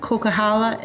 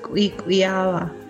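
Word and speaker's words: Coquihalla: In Halkomelem, Kokahala is pronounced Kwikwiala.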